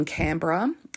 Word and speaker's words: Canberra: Now, Canberra in Australia, we clearly run all words together. It looks like Canberra. And the only person I know who pronounces like that is my stepmom. She pronounces things completely phonetically. And whenever she says Canberra, I'm like, one of my cousins lives in Canberra. But we kind of just say it like it's C-A-N-B-R-A.